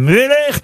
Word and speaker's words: Müller: Et, ou Müller. Ça s'écrit M-U-E-2-L-E-R.